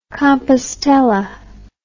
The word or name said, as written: Compostela